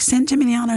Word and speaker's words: San Gimignano: I was like, San Gimignano.